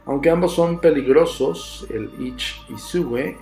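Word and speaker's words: each-uisge: son conocidos como Kelpie o Ichwisge, pronunciado Ichwisge, y comparten ciertos rasgos.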